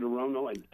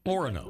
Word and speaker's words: Orono: How do you pronounce it, Orono? – Orono. – Wrong. – Orono. – Oh, then I'll yell because Orono is what I announced it recently.